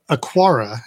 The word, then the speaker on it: Aqara: Well, Aqara, and I guess I'm saying it right, A Q A R A,